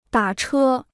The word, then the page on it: 打车: dǎ chē